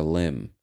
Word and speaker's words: limb: Denoting an artificial body part, such as a limb, a heart, or a breast implant. So I guess it means artificial. More specifically, an artificial body part. And the word limb, L-I-M-B, and the B is silent.